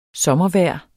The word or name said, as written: sommervejr